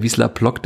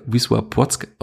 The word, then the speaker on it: Wisła Płock: Vielleicht kommt bald wieder ein polnischstämmiger Hörer, der mir sagt, dass die Aussprache ganz falsch ist, wie bei Wisla Plock, das ja Wisła Płock ausgesprochen wird, von dem Dame Michalski kam.